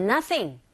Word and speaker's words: nothing: And nothing, nothing. TH 발음하실 때 sing이 아니고 thing, thing 긁어주시고요.